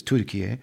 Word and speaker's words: Türkiye: Literally, most of the country of Turkey, which they've officially renamed the way they pronounce it here, which is Türkiye. But forgive me, I'm probably going to say Turkey a lot.